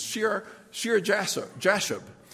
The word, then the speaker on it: Shear-Jashub: I had to look up a pronouncing Bible for these names. It's Shear-Jashub. It doesn't roll off my tongue.